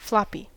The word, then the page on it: floppy: American English